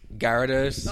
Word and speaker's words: Gyarados: Gyarados. – That's what I call him. What do you call him? – Gyarados. – Oh, Gyarados? – Yeah. Well, you just pronounce it with a different accent.